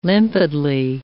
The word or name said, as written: limpidly